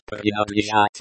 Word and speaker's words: принадлежать: Эта книга будет принадлежать моему другу. – Эта книга будет принадлежать моему другу. This book will belong to my friend. Pronunciation. – Принадлежат. – Принадлежат. The stress falls on the second syllable.